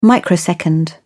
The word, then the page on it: microsecond: British English